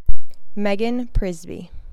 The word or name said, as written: Megan Przybysz